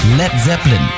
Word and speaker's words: Led Zeppelin: Led Zeppelin. Das A in Led ließ er einfach weg, damit es nicht wie Blei ausgesprochen wird.